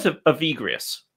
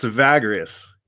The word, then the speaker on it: Evagrius: Okay, let's see if I can say his name right. It's who was Evagrius? – Almost. You almost got it. It's Evagrius. – Evagrius. – Yeah, Evagrius. So I've done a little writing on Evagrius, not much.